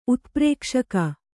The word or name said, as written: ಉತ್ಪ್ರೇಕ್ಷಕ